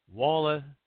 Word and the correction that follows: Wallace: Walla Seafed. Wallace.